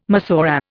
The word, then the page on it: Massorah: Indian